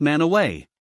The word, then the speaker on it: Mantua: Peg Leg Sam and the Pony Express part way up Sardine Canyon, between Brigham City and Logan, is the tiny town of Mantua, pronounced Manaway.